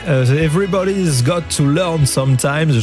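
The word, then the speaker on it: Everybody's Got to Learn Sometime: Donc, ce titre, c'est Everybody's Got to Learn Sometimes. Je regarde si Mathieu il approuve. Ben, ça me va bien alors, il approuve la manière dont je l'ai prononcé.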